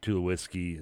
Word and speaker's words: Tulowitzki: bringing in David Price and Tulowitzki,